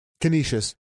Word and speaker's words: Canisius: He was at Canisius. Is that how you pronounce it? – Canisius. Yep.